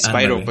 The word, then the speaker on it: Spyro: Ah, Spiro. Eh, eh, Spiro pues creo que se pronuncia.